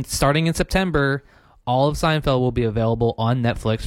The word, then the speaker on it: Seinfeld: And if you haven't seen the show, I hope I'm pronouncing it right. It's called Seinfeld? – Seinfeld, yeah. Also on Netflix.